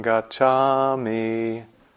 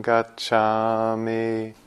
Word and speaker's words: Gacchami: But on the East Coast, we do it Buddhang Saranang Gacchami. Dhammang saranang gacchami. So I think we should honor the East Coast pronunciation here, even though many of you are from the West Coast and inclined that way. So we'll do the flat on the gacchami today.